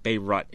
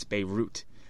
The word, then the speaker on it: Beirut: Yeah, it's not Beirut, it's Beirut.